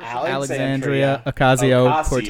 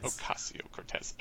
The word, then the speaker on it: Alexandria Ocasio-Cortez: Alexandria Ocasio-Cortez. – Thank you. Cortez. – Ocasio-Cortez.